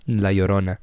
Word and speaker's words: La Llorona: When La Llorona. – That's different. – Oh, okay. – That's like the boogeyman for Mexican folk. – How you say it again? – La Llorona.